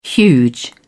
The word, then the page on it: huge: British English